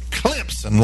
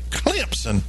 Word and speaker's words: Clemson: It ain't Clemson with a Z. It's Clemson, like C-L-E-M-P-S-E-N. Clemson.